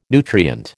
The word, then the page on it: nutrient: American English